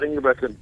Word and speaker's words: Ingebretson: Scott, let's see if I pronounce this right, Ingebretson. Am I close there? – Scott Ingebretson. Very good. Good job.